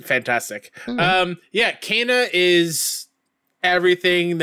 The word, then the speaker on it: Kena: Yep, I got to play an hour of, and I learned because of the gameplay demo, it's pronounced Kena, Bridge of Spirits.